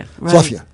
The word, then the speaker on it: Philadelphia: Speaking of the word Philadelphia, it gets pronounced more and more, I think, like Fulladelphia, F-U-L-L. Is that something you've observed? – It was pointed out by a columnist for the Inquirer that the best way to pronounce Philadelphia and be local is F-L-U-F-F-Y-A. – Oh, Philadelphia. – Fluffia. – Right. – And the real fast. So there's a tendency for all cities to try to contract the name into a single syllable, and sometimes they make it like Balmer. – Balmer, right. – But Fluffia is still two syllables.